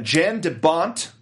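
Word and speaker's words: Jan de Bont: I'm going to try to say that correctly. Jean de Bont. For our American viewers out there. – That sounds right.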